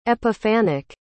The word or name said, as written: epiphanic